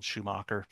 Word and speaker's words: Schumacher: My last name is not spelled how it sounds. It's spelled Schumacher.